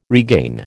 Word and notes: regain: American English

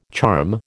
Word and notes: charm: American English

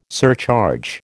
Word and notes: surcharge: American English

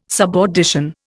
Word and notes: subaudition: Indian

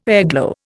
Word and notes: airglow: Indian

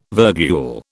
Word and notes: virgule: British English

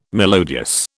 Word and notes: melodious: British English

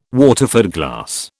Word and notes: Waterford glass: British English

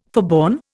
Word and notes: forborne: Indian

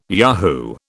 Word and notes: yahoo: British English

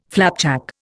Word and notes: flapjack: Indian

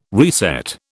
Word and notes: reset: British English

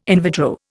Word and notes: in vitro: Indian

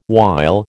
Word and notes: wile: American English

